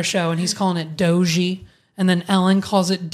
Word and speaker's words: Doge: It's Doge. Doge is how you say it.